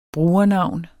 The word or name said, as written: brugernavn